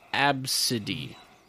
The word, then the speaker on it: Abcde: ABCDE, as supposed to be pronounced Absidy.